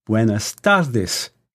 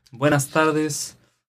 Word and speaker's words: buenas tardes: Buenas tardes. Good afternoon. Buenas noches. Good evening. How was that? – Excellent. Excellent pronunciation. Buenos dias. Buenas tardes.